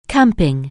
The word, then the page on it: camping: anglais